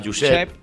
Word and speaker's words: Josep: Giuseppe sería en italiano, en catalán sería Josep. ¿Vale? Entonces, en el castellano antiguo era Josepe. Entonces es más fácil. – ¿Cómo pronuncias el catalán? – Es más, Josep.